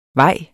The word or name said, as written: vej